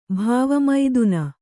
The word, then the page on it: ಭವಮಥನ: bhava mathana